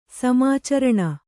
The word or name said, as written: ಸಮಾಚರಣ